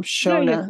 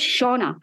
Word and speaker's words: Shona: No, you're actually saying it the Scottish way. The Scottish way is Shona. But it's Shona, it's spelled in the book, because nobody would understand the Scottish spelling and it would cause havoc. So I just put the English version. So you're actually right.